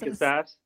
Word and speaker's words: Casas: What's so Casas, is that how you say it? Casas, Tristan Casas? – Casas.